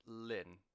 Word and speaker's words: LLNN: And I believe they're pronounced Lynn. They are from Copenhagen. They do a very like expansive post-metal form of sludge metal, and they sound fucking incredible. But it's spelled L-L-N-N. And I think it's pronounced Lynn, so either way, if not, L-L-N-N. Mastodon, Chondra, Lynn, or L-L-N-N, just to be fucking difficult.